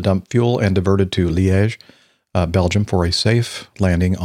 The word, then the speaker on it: Liège: Yeah, Liège. – Liège.